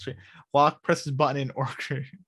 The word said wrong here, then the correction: orchid: Did I say that? Sorry, orchard? – Orchid. – Orchid. Yes, It's like ORKID. Orchid. – See, no, it's not. Orchid. – That's not how you spell it, by the way, folks. I know that it's C H, not K, but just phonetically, it's ORKID.